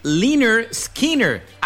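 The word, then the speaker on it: Lynyrd Skynyrd: Me refiero a Liner Skinner, el grupo que para muchos le es muy complicado pronunciar su nombre. Tanto así que ese álbum debut se llamó, se pronuncia, pronounce Liner Skinner.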